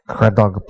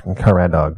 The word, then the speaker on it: Caradog: The antagonistic man they encounter on the road to the house is Craddock Pritchard. It looks like it would be pronounced Caradog, but it's Craddock Pritchard.